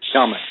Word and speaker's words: Calmet: How do you pronounce it again? – Calmet. – C-A-L-M-E-T. Calmet. Yeah.